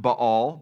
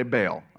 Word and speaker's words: Baal: I think technically it's actually pronounced Baal, but you sound like a psycho, so I'm going to say Baal, okay?